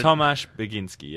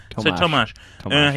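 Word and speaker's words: Tomasz Bagiński: The director, and you just be on standby for pronunciation, Tomasz Baginski. It's highlighted. – Tomasz Baginski, yeah. – There we go. – I need to back myself more with Tomasz. The pronunciation. Tomasz.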